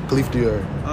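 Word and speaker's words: Khaleaf Dior: My Instagram is Khalif Dior. TikTok, Khalif Dior. YouTube, Khalif dash Dior. That's spelled K-H-A-L-E-A-F dash D-I-O-R. Khalif Dior.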